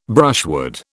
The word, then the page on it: brushwood: British English